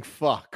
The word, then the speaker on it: pho: RJ says that I get bonus points for pronouncing pho correctly.